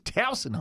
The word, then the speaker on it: Towson: Well, yes, but the point of I pronounce it with the Baltimore emphasis. Towson, hon, you gotta go.